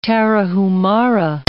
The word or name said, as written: Tarahumara